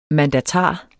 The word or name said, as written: mandatar